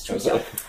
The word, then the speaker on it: Trousdale: And, yes, Truesdale.